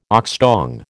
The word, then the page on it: oxtongue: American English